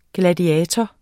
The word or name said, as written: gladiator